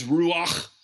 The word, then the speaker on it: ruach: So in Hebrew, the word is ruach, and you got to say it like that.